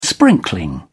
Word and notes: sprinkling: British English

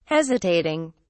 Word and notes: hesitating: British English

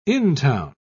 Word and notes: intown: British English

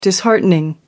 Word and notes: disheartening: British English

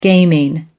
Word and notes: gaming: American English

